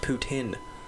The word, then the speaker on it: poutine: Apparently, it's pronounced poutine. – Poutine for you Quebecers out there.